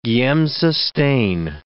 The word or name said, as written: Giemsa stain